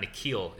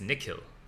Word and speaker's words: Nikhil: So like, your name's not Nikhil, it's Nikhil.